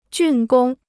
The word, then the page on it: 竣工: jùn gōng